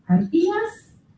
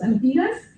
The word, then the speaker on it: Artigas: autor de obras como Artiguas y luego. – Ni amoñaré. – Así lo pronuncia él. Nosotros lo pronunciaríamos, Artigas.